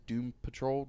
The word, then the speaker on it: Doom Patrol: Well, one of our good friends, Nate from the at Ninja Fat Man on Twitter, and he's got the Doom Patrol.